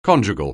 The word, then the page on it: conjugal: anglais